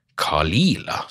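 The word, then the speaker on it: Caol Ila: som er Kalila. Kalila, sånn uttales det.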